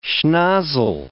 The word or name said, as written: schnozzle